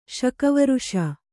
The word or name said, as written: ಶಕವರ್ಷ